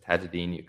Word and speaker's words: Taajuden: And then my Instagram is just Tajuddin Yaqub. So kind of how my name is spelled, T-A-A-J-U-D-E-N dot Yaqub, Y-A-K-U-B.